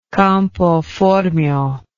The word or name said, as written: campoformio